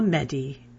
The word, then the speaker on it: Mehdi: I've got to tell you, it is a lovely hotel that was enhanced by the services of a private butler named Mehdi. Ah, Mehdi, and it's spelled M-E-H-D-I.